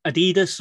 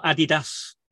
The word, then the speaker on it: Adidas: If you take Adidas or Adidas, depending on how you pronounce it, where you are in the world. For me, it's Adidas.